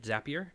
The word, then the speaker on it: Zapier: So Adam, you are currently at Zapier, and that's right. Good pronunciation.